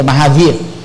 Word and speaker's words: Mahavir: nó đọc nhẹ đó là mahavir và là mahavira uh, viha thôi chứ là mahavir người ấn độ á, phát âm là mahavir thì tương tự chữ uh,